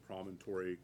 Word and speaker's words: promontory: And can you tell me where is, first of all, is it, is the way Commissioner Weefald pronouncing promontory correct? – No. – Okay, thank you. Where? – How do you pronounce it then? – Promontory. – Oh, thank you.